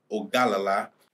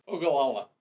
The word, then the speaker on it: Ogallala: as they get ready for Ogallala. Yeah. Am I saying that right? – No. Ogallala.